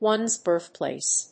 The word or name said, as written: one's birthplace